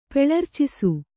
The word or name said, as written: ಪೆಳರ್ಚಿಸು